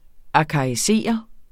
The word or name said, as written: arkaisere